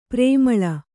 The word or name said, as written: ಪ್ರೇಮಲೆ